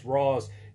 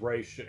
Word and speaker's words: Ra's: Uh, and for those of you that are wondering, yes, I pronounce it as Ross. He pronounces it as Raish. And before anyone starts to, well, you're wrong and he's right. It has been it has been officially stated that both pronunciations are correct. Just really depends on what type of dialect.